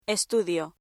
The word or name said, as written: estudio